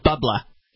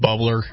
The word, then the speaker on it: bubbler: They do the New England style bubbla here. Suspect it's pronounced a little differently. – Yeah, over there it's pronounced bubbler.